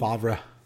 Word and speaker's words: Favre: I mean, there's like no, I've never heard of Favre in my life, and it doesn't look like, it's not pronounced how it's spelled. – Favre.